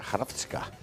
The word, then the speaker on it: Hrvatska: Es como se escribe Croacia en croata, Hrvatska. Una cosa rarísima. – Qué bien lo pronuncias.